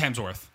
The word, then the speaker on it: Hemsworth: It's Hemsworth. – Hemsworth, not Hemsworth. – There's no fucking L in there. It's just Hemsworth.